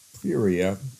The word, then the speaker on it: Perea: That's how I pronounce it, P-I-E-R-A, Perea, until leaving for Jerusalem shortly before the Passover week.